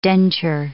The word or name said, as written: denture